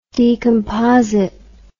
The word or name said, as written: decomposite